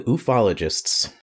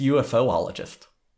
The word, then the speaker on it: ufologist: All right, today we're becoming ufologists. – You you want to take another try at that? I think you mispronounced that word. – Ufologists? – It's UFOologist. – No, it's ufologist.